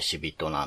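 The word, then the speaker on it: 屍人: うん、死人、屍に人と書いて死人なんですが、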